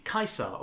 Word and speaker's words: Caesar: living through the reign of Caesar Augustus, or if you want to pronounce it correctly, Caesar Augustus.